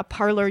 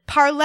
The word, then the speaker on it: Parler: And so then I saw it in print and it occurs to me that it's they're mispronouncing it because it's a French word, parler, and it's in the infinitive form of the verb, which in English trans- translates to to speak or to talk.